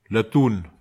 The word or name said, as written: latun